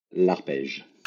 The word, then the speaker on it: L'Arpège: L'Arpège. – L'Arpège.